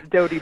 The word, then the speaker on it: Doughty: New York Times bestselling author and mortician, Caitlin Doughty. Am I pronouncing that correctly? I guess I didn't mention that. – You are. Yes, it's Doughty, bread dough t-shirt.